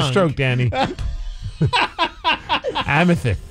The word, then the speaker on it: amethyst: Are you trying to say amethyst? – Yeah.